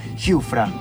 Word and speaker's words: Giuffra: Nos acompaña Manuel Azurmendi y María Giufra.